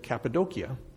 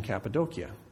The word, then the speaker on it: Cappadocia: Until a year ago in September, I always said Cappadocia. In Turkey, they pronounce the region Cappadocia, and so I call it Cappadocia now.